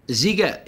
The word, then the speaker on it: Sziget: So you're just coming off the back of the, am I pronouncing it correctly, the Sziget Festival in Hungary? – Sziget, yeah. I think it's Sziget. I mean, I'm not Hungarian, but Sziget is what I was calling it, and no one corrected me, so hopefully that's a thing.